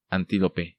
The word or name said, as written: Antilope